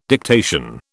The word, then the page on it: dictation: British English